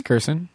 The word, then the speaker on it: Kirson: As indicated, by the way, I might have bungled her last. Does anyone know Kirsten? – I think it's Kirsten. – Kirsten. – How do you spell it? K-R-I-S-O-N. – K-I-R-S-O-N.